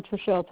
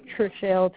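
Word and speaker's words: Trachelle: Trishelle Thomas. – I'm sorry? – Trishelle Thomas.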